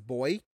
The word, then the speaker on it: buoy: And then I'm just learning, you know, Australian lingo, and, like, I found out they pronounce the word buoy as boy.